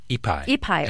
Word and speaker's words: Ipai: As early as 1772, Father Luis Jaime Orjamia of Mission San Diego reported hearing that local Indians, probably, is it Ipay or Taipei? Is that how you say it? – Ipai or Tipai people, quote, leave their huts and the crops which they gather and go to the woods and experience hunger.